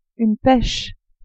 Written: peche

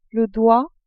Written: doigt

